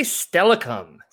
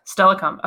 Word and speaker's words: Steilacoom: Did I pronounce that correctly? – I think they say Steilacoom. – Steilacoom.